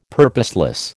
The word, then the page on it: purposeless: American English